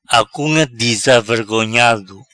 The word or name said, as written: Acunga dizavergonhado